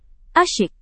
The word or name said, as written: asyik